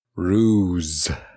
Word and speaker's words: ruse: We talked about this. – Oh. – Say it right. – Ruse. – There we go.